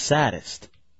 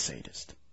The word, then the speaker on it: sadist: But she says it's pronounced sadist. – No. – That's not right. It's sadist.